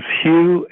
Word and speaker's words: Hugh: Yes, my name is Hugh, H-U-G-H. The last name is Charlson. It's spelled T like Tom, R-A-U-L-S-E-N like Nancy.